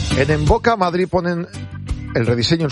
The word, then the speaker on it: Mvoca: En Boca tiene descuento del 20% para los oyentes de Esradio. Y en Boca se escribe M-V-O-C-A. En Boca. Primera consulta, estudio gratuito. Está en Velázquez 96. M-V-O-C-A.